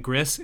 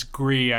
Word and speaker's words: Gris: Also, by the way, last week we were pronouncing that the game, we were saying Gris. It's Gree, I learned.